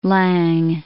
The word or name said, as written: Laing